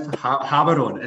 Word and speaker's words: Gaborone: is Botswana Gaborone. – Yeah, pronounced Haberon. It's pronounced Haberon.